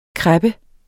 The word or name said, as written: kreppe